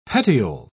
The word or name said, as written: petiole